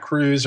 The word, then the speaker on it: Kruse: I would tell most people, if you've never even heard of CT, just go to my website, Jack Cruz, and my last name is spelled K-R-U-S-E.